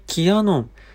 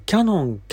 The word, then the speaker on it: キヤノン: がありましてキヤノンでございます。この記事をご説明いたします。まずは見出し、暗闇でもカラー撮影、これが大見出しで、新センサー、キヤノン、車など応用もとあります。ところでキヤノンとあえて言ってますが、よくキャノン、キャノンと、やが小さく発音する方いらっしゃいますが、正式にはキヤノンと、やも大きいんですけども、これはキヤノン。